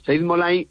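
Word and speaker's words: Saeid Mollaei: Como es Said Molay, creo que lo dije bien, ¿no? – Sí, sí, sí, lo has dicho perfectamente. Said Molay es campeón del mundo, subcampeón olímpico, es un judoka iraní.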